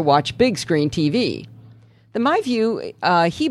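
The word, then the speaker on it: MyVu: Well, today he stopped by my house to show off his latest gadget, the MyView.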